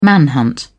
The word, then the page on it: manhunt: British English